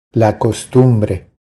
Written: La costumbre

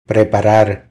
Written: Preparar

